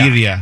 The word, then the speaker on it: birria: Is that how you pronounce that? Birria tacos? – Birria. – Birria. Birria tacos. Yeah, I probably knew I had that wrong.